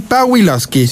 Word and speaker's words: Pawlikowski: Paweł Pawlikowski. ¿Lo dije bien? Pawlikowski. – Sí, por supuesto.